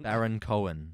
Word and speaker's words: Baron Cohen: You're Sacha Bowen Cohen? – Baron Cohen. – Yes, that's it. You nailed it.